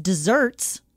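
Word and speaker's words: deserts: Also, while we're on the topic, just desserts is things you deserve. And that's why it's spelled with one S, even though it's pronounced desserts.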